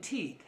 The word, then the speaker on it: Tet: The 9th letter of the Hebrew is T-E-T, and it's pronounced like in teet, het, het.